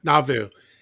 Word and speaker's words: Nauvoo: Nauvoo.